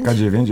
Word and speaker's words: K9: K9 jest to, wynika to po prostu z angielskiego słowa canine. K9 wymawia się w ten sam sposób i dotyczy psowatego, kłów.